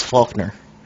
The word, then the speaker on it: Faulkner: The first one is Faulkner. – You said it right.